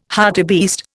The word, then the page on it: hartebeest: Indian